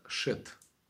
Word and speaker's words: Шет: Но в еврейском это читается как Шет.